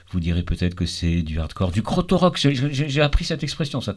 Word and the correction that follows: Krautrock: Je prononce pas bien. Krautrock, c'est un...